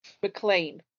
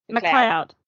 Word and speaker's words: McLeod: So at the age of 18, she answered an advertisement in a Dutch newspaper placed by a Dutch colonial army captain, Rudolf McLean. – McLeod.